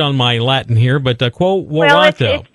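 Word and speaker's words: quo warranto: You wrote an article the other day, quo, is it pronounced quo waranto? – Yeah. – Is that how you... – quo waranto. Yeah, it's weird. It's quo waranto.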